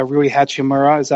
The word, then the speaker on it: Rui Hachimura: Rui Hachimura.